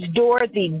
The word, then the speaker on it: Dorothy: My name is Dorothy, D-O-R-O-T-H-Y. My last name is spelled S-T-R-I-C-K-L-I-N.